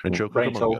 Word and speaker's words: Rancho Cucamonga: Was also part of Ranch Cucamonga. Is it Ranch Cucamonga? Am I saying that right? – Rancho Cucamonga. – Rancho Cucamonga.